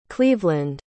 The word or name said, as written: cleveland